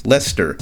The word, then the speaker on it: Leicester: So, silent ice is very similar, like the city of Leicester in England. It is spelled L-E-I-C-E-S-T-E-R, and somehow they pronounce that Leicester, as if there's a silent ice in there.